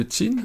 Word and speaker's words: Qin: Au départ, pratique, matériel, de ce petit état, peut-être du Wei, puis après de Qin, je ne sais pas très bien prononcer. – Oui, c'est ça, Wei et Qin.